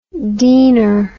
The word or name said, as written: deener